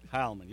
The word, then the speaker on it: Heilman: Heilman, yes, sir. – Oh, I got it right. Anyway, the E is silent.